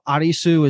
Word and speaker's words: Arisu: It's a very known thing that L's are difficult to pronounce and they're usually substituted with like an R. Mm-hmm. So Arisu is Alice. That's like obvious. And Arisu. – Duh. – Arisu Alice. It literally sounds like it, you know, from yeah.